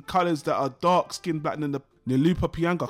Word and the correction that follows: Lupita Nyong'o: I can't even say her name. You know, the chick from Star Wars. I can't remember. – Lupita Nyong'o. – Yeah, thank you.